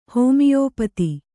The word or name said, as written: ಹೋಮಿಯೋಪತಿ